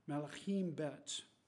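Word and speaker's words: Malachim Bet (מלכים ב): In the Hebrew, Second Kings is pronounced Malachim Bet.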